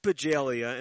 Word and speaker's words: Bajalia: So it's Jeanette Bajalia, and that's spelled B-A-J-A-L-I-A, correct? – Correct.